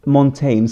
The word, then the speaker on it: Montaigne: Montaigne skulle jag säga. – Montaigne. – Montaigne, ja.